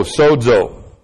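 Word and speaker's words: sozo (σῴζω): That's S long O, Z long O, sozo.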